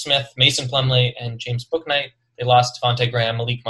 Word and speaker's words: Bouknight: Bouknight, that's how you say it. Bouknight.